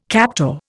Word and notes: captor: Indian

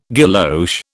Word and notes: guilloche: British English

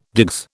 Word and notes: digs: British English

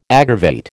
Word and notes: aggravate: American English

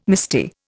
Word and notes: misty: Indian

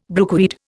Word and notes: brookweed: Indian

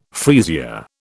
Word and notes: freesia: British English

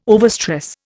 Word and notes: overstress: Indian

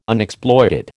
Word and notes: Unexploited: American English

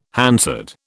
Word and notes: Hansard: British English

